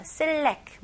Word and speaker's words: select: 근데 이거는 영어 발음은 select. select. select. OK. 여기에 강세가 어디에 들어갈까요? 다시 한번 해볼게요. Select. 네, 두 번째 음절에 들어갑니다. 그래서 select, select. 그리고 끝까지 T 발음 해주셔야 돼요.